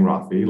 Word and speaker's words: Raffi: So it's spelled just like my name, R-A-F-F-I, which is my first name, Rafi, last name, Andonian, A-N-D-O-N-I-A-N.